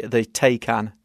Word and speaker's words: Taycan: It's now going to be called the Taycan. – How do you spell it? – T-A-Y-C-A-N. So it's like the, what was the movie with Liam Neeson? – Taken. – So it's kind of, think of that.